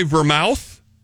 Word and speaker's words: vermouth: So you have Velveeta cheese-infused vodka, an olive brine, dry vermouth, and it's garnished... – Vermouth. That's pronounced vermouth. – Have you seen how it's spelled? – Yes, I have.